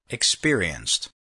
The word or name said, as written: experienced